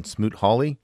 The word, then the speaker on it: Smoot Hawley: You know, and I just heard a really fascinating Planet Money podcast on Smoot Hawley. – Yeah. – Am I saying that right? – Yeah.